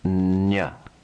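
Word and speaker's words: nya (ञ): The letter is pronounced nya, nya, nya.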